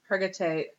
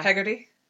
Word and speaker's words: Hargitay: Is that how you say it? – Hergate. Yeah. – Hegarty? – Hergate.